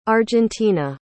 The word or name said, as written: argentina